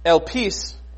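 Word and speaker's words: elpis (ἐλπίς): But this word hope that we have, I'm just going to throw, elpis is how we pronounce it in Greek.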